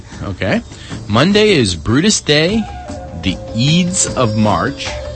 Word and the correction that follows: Ides: Oh, the Ides of March.